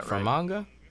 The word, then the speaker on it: Fermanagh: Fermanagh. – Yeah, that's definitely not it. – Yeah. – Do you have the spelling? – Yeah, it's Fermanagh. – That sounds close. Yeah, Fermanagh. Right. Fermanagh.